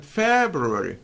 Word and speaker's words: February: Look, same thing. February.